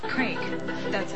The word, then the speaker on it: Craig: It is pronounced Craig. – C-R-A-I-G. – Craig. That's it.